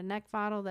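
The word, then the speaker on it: Neckvatal: And if you'd like to connect with me professionally, you can find me on LinkedIn just by typing in my name, Brenda Neckvottle. That's spelled N-E-C-K, like the thing you want to choke. V as in Victor, A-T-A-L.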